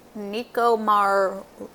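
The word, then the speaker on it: Nikumaroro: Yeah, it's now called Nicomaroro, which is south of the island. – Perfect pronunciation. flawless execution.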